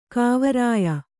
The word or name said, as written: ಕಾವರಾಯ